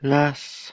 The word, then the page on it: las: Lwaitel